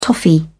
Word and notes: toffee: British English

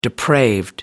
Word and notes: depraved: American English

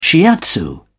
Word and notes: shiatsu: British English

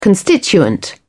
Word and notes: constituent: British English

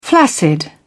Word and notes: flaccid: British English

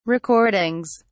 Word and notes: recordings: British English

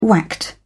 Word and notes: whacked: British English

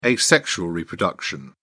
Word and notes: asexual reproduction: British English

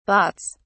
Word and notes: bahts: British English

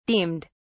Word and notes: deemed: British English

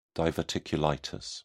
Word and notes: diverticulitis: British English

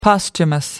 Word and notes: posthumous: American English

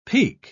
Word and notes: peke: British English

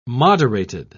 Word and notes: moderated: British English

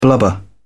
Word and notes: blubber: British English

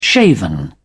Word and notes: shaven: British English

